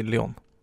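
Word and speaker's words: Léon: För det är väl det han heter? – Uh, nej, han heter ju Leon.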